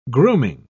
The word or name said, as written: grooming